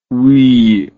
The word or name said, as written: oeil